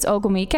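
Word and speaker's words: Ogwumike: Neko, I think it's Ogumike.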